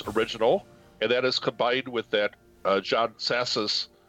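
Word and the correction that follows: Psathas: I'm not really sure how to pronounce it, The View from Olympus. – I think it's Sathis. – Sathis, thank you.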